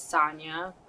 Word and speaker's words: Sanja: So, like I said, my name is Sonya, but, everyone calls me Sanj, because it is spelled with a J, which is honestly a very big talking point when people first meet me.